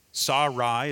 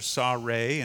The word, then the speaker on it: Sarai: Because Abraham, as you remember, was a man who was not able to have any children with his wife Sarai, is how you would pronounce that, or Sarai, and then later Sarah.